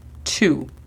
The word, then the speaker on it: two: La pronunciación fonética sería U-A-N. One. Two. Dos. La pronunciación fonética sería T-U. Two. Nota cómo no es tú, es two. Recordando que para lograr este sonido estamos casi haciendo el sonido con nuestros dientes y la lengua. Two. Three. Tres. La pronunciación fonética sería T-H-R-I.